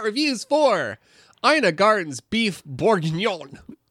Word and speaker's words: bourguignon: I promised that I would look up how to pronounce it because I didn't want to sound like an idiot. – Bourguignon. – I forgot. But y'all know, y'all can picture the word. It's bourguignon.